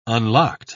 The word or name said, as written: unlocked